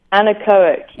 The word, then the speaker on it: anechoic: I want to mention, I looked this up, and you spent some time at Bell Labs, and that's where the, I might mispronounce this, it's anechoic. How do you pronounce it? It's no echo. – Anechoic.